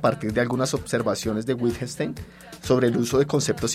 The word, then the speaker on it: Wittgenstein: Wittgenstein.